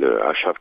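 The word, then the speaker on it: Ašav: cette association, donc, s'appelle asav 92, A-S-A-V, avec un, petit V sur le S, qui fait que ça se prononce ASAV et que langue romanie, ça veut dire euh, s'arrêter, s'arrêter, palabrer, se mettre d'accord.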